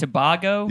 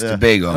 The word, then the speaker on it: Tobago: I think I said Tobago. – Yeah. – Yeah, Tobago. It's Tobago. Yeah. – Okay, there we go. – Yeah, he said Tobago.